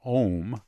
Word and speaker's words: Ohmme: They're called Ohm. It's spelled O-H-M-M-E.